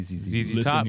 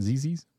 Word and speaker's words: ZZ Top: Z. Z. Top, baby. – You know, have you guys heard of the band ZZ's? Am I saying it right? ZZ's? Or is it just ZZ? – ZZ Top.